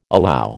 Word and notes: allow: American English

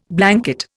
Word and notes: blanket: Indian

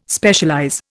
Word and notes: Specialize: Indian

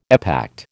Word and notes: epact: American English

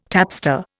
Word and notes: tapster: Indian

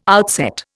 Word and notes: outset: Indian

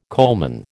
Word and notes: coalman: American English

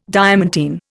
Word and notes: diamantine: Indian

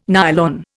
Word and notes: nylon: Indian

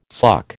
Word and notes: suck: American English

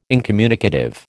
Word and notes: incommunicative: American English

